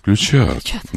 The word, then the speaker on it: ключат: Ключат. На ключ не падает ударение никогда, кроме слова «ключ».